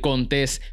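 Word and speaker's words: Contés: Mi nombre es Miguel Contes, con acento en la E, y esta es la manera de empezar tu día correctamente.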